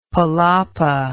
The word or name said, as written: palapa